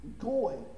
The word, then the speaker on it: goy: Goy. G-O-E is the pronunciation. The spelling is G-O-W-Y.